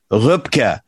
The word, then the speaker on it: Röpke: With an umlaut over the O, or you could spell it R-O-E-P-K-E, Röpke.